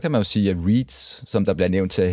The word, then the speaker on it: REITs: Jeg synes sjældent, der bliver snakket om REITs i programmet, altså REITs, Real Estate Investment Trust. – REITs. – Nå, er det REITs, det udtales? – Ja, det vil jeg, som jeg kalder.